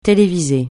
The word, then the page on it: téléviser: francais